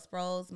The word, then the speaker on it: Sproles: My personal is Michelle Sproles.